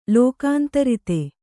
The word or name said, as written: ಲೋಕಾಂತರಿತ